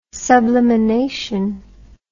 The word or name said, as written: sublimination